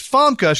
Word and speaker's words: Famke: So, did I say that right? It's Famke. She goes, yes, Janssen.